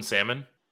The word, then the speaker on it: salmon: Pronouncing that hard L in salmon? – Salmon.